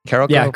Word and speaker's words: Carolco: They owned Carolco, how do you say it? – Yeah. – Carolco Pictures? – Carolco, yeah.